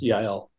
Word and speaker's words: Yael: She goes by at Yael Writes, which is Y-A-E-L on Twitter. – And the name is pronounced Yael.